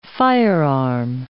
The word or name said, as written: firearm